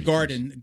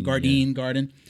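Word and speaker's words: Gardein: So there's a brand called Garden, but it's spelled at the end D-E-I-N. Garden.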